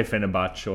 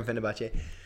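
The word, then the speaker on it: Fenerbahçe: How do you pronounce that? – Yeah, I'd agree with that. I say Fenerbahce or Fenerbahce. – Okay, let's go with Fenerbahce.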